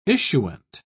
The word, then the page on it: issuant: British English